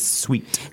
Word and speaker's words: suite: This looks almost like the word suit, but it has an E at the end. So S-U-I-T-E. – Yeah. And the pronunciation is sweet. – Sweet. Just like sweet. Sugar. – Like sugar is sweet. – Yeah. It just has very different spelling.